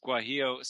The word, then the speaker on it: guajillo: It's guajillo.